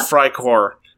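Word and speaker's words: Freikorps: Matt does not know how to pronounce Freikor. I'm sorry. I know he's a developer, but it's Freikor. It is German. That's how you pronounce that word.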